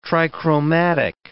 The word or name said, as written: trichromatic